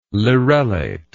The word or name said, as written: lirellate